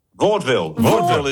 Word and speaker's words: Vaudeville: Vaudeville is the correct pronunciation.